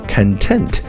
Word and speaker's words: content: content 这个词呢，如果重音靠后读成 content，就成了形容词满足的啊。